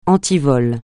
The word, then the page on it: antivol: francais